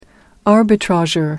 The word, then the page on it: arbitrageur: American English